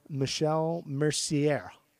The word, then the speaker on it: Michel Mercier: Black Sabbath took their name from, which starred Michel Mercier. – That's a great pronunciation. – Yeah, probably how you say it.